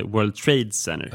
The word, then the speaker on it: World Trade Center: Det finns ju många sådana svenska uttal som till exempel World Trade Center. Fast det heter World Trade Center. – Ja, just det. World Trade Center, ja. Just det, ja. Man betonar center.